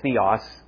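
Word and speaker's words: theos (θεός): You might notice when I say the word theos, and in some pronunciation systems, it's theos. Pronunciation system I learned is theos. It sounds a bit like the word theology.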